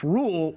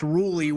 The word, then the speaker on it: Rhule: Matt Rhule, who it's just Rhule, who, I mean, I told me to my face that his name is pronounced Rhule when he was coaching Temple and was playing in the Boca Raton Bowl.